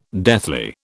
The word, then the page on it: deathly: British English